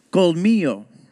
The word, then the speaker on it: colmillo: So, what he just said, and he taps his tooth when he goes, it's colmillo.